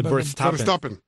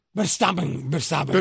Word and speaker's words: Verstappen: De Verstappen. – Verstappen.